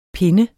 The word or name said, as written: pinde